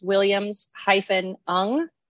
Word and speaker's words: Williams-Ng: And so I'm Stacey, but my last name is Williams-Ng, which is spelled NG.